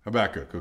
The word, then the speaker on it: Habakkuk: Amos, Obadiah, Jonah, Micah, Nahum, Habakkuk. Did I say it right? – Habakkuk, okay.